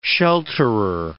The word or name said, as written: shelterer